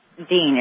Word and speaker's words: din: because Islam uses the word deen. It's spelled D-I-N, but it's pronounced like it's D-E-N, D-E-E-N.